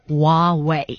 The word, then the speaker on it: Huawei: It's just Huawei. – Mm-hmm. – So there's Netsu, so it's Hua and then Wei.